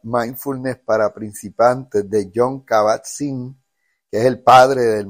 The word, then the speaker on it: Jon Kabat-Zinn: Jon Kabat-Zinn. Y se escribe, o sea, John J-O-N, Sabat, como se pronuncia, y con Z, Zinn.